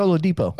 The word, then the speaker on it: Oladipo: Oladipo. – Oh yeah, Victor Oladipo.